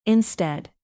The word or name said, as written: instead